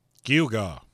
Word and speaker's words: gewgaw: For those that are curious, it's spelled G-E-W-G-A-W, and it's pronounced Dugar. Like the creepy deep voice said a few seconds ago.